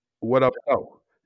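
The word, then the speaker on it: What up doe: What up, doe? – No. What up, doe? Yeah, you can't stress the doe. Is what up, doe? Is all one, what up, though? – What up, though?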